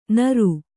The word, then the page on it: ನಾರ್: kannada